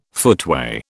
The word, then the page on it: footway: British English